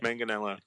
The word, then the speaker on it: Manganiello: Oh, how do I say his name? Shit. Are you familiar with the actor Joe Manganiello? – Manganiello. – Joe Manganiello. Manganiello? – Manganiello, yeah.